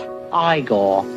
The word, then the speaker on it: Igor: Now it's pronounced Igor.